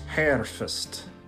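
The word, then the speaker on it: hærfest: Anyway, so Old English's version of the word was herfest, spelled H-A-S-H-R-F-E-S-T. Ash is an archaic letter that looks like an A and an E got smashed together. It's usually pronounced like the A in cap or tap.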